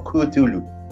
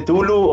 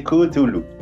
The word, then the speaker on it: Cthulhu: Cthulhu. No se dice Tulu o Chatulu o nada de eso. Busquen Ah, en, estaba en internet y era que se dice Cthulhu. Esa es la, primera cuestión que tienen que saber.